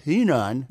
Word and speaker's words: Henan: which is pronounced Henan.